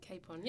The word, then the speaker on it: Capon: Capon, yep. – Capon. – Yep. I kind of say like Superwoman puts a cape on.